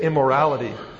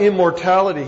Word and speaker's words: immortality: And this word immortality, I cannot tell you how many times I've heard people read it and by accident they say immorality. Jesus did not bring immorality. Jesus brought immortality.